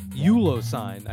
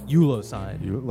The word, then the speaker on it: Euglossine: Euglossine, I believe is how you say it. Euglossine. – Like a silent G? – Yeah, like Euglossine. Euglossine.